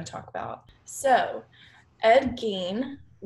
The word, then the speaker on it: Gein: Oh, no, it's Gein, G-E-I-N, yeah.